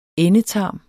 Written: endetarm

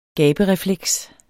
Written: gaberefleks

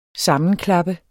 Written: sammenklappe

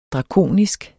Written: drakonisk

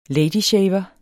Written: LADYSHAVEREN

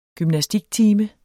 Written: gymnastiktime

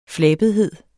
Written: flabethed

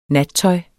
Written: nattøj

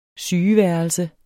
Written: sygeværelse

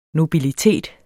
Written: NOBILITETERNES

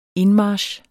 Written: indmarch

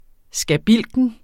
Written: skabilken

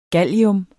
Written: gallium

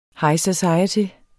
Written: society

